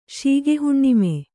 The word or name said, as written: ಶೀಗೆಹುಣ್ಣಿಮೆ